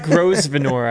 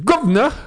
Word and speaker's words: Grosvenor: And if you pronounce it Brit, it's Grosvenor. – Yeah. – Yeah. So everything just becomes one syllable.